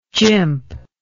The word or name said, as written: jimp